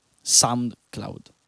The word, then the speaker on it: SoundCloud: SoundCloud. Ok, detto bene, sì.